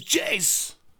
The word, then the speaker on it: Jace: My name's Jace. – And it rhymes with face.